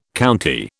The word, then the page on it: county: British English